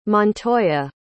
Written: montoya

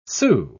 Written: sue

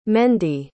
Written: mendy

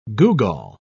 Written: googol

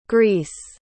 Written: greece

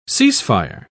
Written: ceasefire

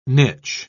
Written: niche

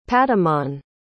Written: patamon

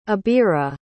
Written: abira

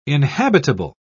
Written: inhabitable

